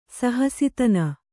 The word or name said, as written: ಸಹಸಿತನ